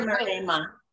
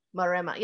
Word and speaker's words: Maremma: Did I get all those right? – You did. – Oh, well, I knew Bitter Maremma. – Maremma, yeah.